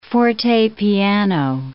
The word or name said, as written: fortepiano